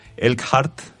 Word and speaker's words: Elkhart: Perdón, lo pronuncié como si fuera alemán. Elkhart, creo que suena mejor.